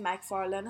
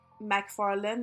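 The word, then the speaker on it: MacFarlane: اسمش مکفارلن مثل مکدونالد اسپل میشده ام سی ولی نه ام ای سیه که مثلا اینا میگن خب اصلا تلفظش عوض میشه اینجوری مکفارلنه ولی اونجوری میشه مکفارلن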